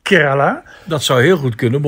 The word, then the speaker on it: Kerala: Kerala Zeg ik dat goed? Dat zeg je goed.